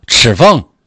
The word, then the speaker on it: 赤峰: 赤峰。